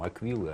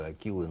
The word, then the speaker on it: аквилы: Ну, как у римлян, у них же были эти там, как они там, аквилы, акилы называются. Аквилы. – Да. – Аквилы, точнее, если правильно говорить.